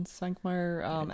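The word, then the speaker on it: Švankmajer: I love, if we're recommending things, I really love the Svankmajer, which I'm going to butcher the name. Is it Jan Svankmajer Alice movie? – Yeah.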